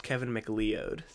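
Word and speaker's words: Kevin MacLeod: I don't want to be a nudge, but it's pronounced Kevin McLeod, so...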